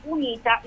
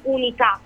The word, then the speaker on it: unita: Allora, intanto il motto dell'Unione Europea è unita nella diversità e non unità, come molti parlamentari si ostinano a dire durante i vari incontri. Non c'è questo accento.